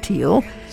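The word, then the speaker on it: Thiele: What a Wonderful World, a song written by Bob Thiel, sometimes pronounced Thiel, who wrote under the pseudonym George Douglas, and George David Weiss, was first recorded by Louis Armstrong on August 16th in 1967.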